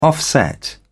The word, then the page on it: offset: British English